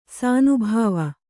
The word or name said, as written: ಶಾನಬಾವ